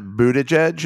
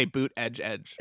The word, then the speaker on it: Buttigieg: But I mean, only because Laura was like, I heard that Mayor Pete, which is, the name is actually pronounced Buttigieg. Is that right? – Buttigieg. – Buttigieg. That's going to be T-shirts that say boot edge edge. – It's boot edge edge, which doesn't make it easier, to be honest with you.